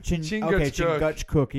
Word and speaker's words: Chingachgook: Is it Chingachgook. – Chingachgook.